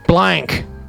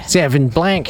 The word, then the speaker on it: Sauvignon Blanc: I pronounced the C too much. – Blank. – Yeah. – Sauvignon Blanc.